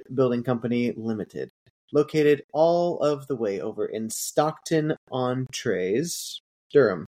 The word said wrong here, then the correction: Stockton-on-Tees: Located all of the way over in Stockton-on-Tees, Durham, England, United Kingdom.